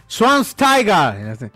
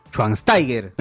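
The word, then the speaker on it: Schweinsteiger: Ah, pues dice, Swans Tiger. – Schwansteiger.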